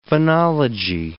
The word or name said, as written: phonology